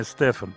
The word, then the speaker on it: Стефан: Глория Стефан, ударение Стефан, правильно.